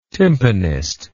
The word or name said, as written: tympanist